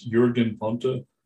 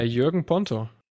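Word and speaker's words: Jürgen Ponto: Is it Jürgen Ponte? – Exactly. Nice pronunciation.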